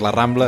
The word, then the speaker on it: La Rambla: Això es La Rambla, això sembla La Rambla.